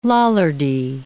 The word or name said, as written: Lollardy